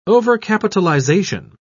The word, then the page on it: overcapitalization: British English